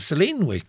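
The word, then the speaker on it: Solène: And this is where Hayes Campbell, the character played by Nellix Galitzine, comes into Selena. Is it Selene? There's the little accent on the E now. It's explained to me.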